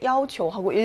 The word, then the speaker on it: 要求: 네, 이렇게 要求라는 단어일 경우에는 일성으로 읽는다.